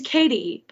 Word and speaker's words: Cady: Also, a really fun little, maybe not Easter egg, maybe I'm using that term incorrectly, but her name's Katie, but it's spelled C-A-D-Y like Katie in Mean Girls.